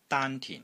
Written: DAAN1 TIN4

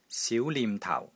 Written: SIU2 NIM6 TAU4

